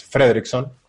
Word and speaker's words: Fredrickson: Es Fredrickson, con acento en la E, o sea, no lleva acento, pero bueno, estamos así. Es sueco.